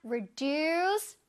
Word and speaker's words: reduce: reduce, reduce. 시작! reduce, reduce. 좋아요. deuce 소리를 내주는 거야. reduce가 아니라 reduce. 오케이?